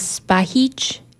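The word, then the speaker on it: Spahić: My last name is Spahic. I'm Bosnian, so that's how it's pronounced. There's a little accent on the C.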